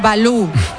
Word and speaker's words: Valou: Este Balú es con V, ¿eh? No es como el oso. – Sí, Yanis Balú. V-A-L-O-U. Creo que lo digo bien, ¿no?